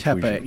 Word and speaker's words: Tepe: Which Tepe. You're right. Pe. Not P. – Yeah. – Göbekli Tepe. – Tepe? – Tepe. P-E-H. Tepe. – Tepe. Okay. – Yeah. Göbekli Tepe.